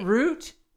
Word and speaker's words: Rut: Is it root? – It is root. – Okay, it's spelled rut. – It is spelled differently, yes.